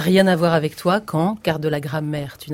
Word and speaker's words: Kant: Alors, ça s'écrit comme Kant, le philosophe, mais ça se prononce Kant, comme dans la phrase « dit quand reviendras-tu » ou « quand je pense à Fernande », un mot qui peut être conjonction ou adverbe selon.